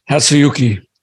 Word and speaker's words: Hatsuyuki: Hatsuyuki.